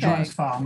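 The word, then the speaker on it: Joann Sfar: And I do not know if I'm saying, is it Jean Svar? – Yeah, how do you say that? – Joan Sfar or Joan Sfar, it depends. – Okay. – Joan Sfar, most of the time.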